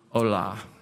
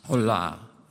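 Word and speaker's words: Olah (עולה): Let's say the Olah. Olah. Sounds like Olah in Spanish, right?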